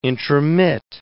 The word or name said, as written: intromit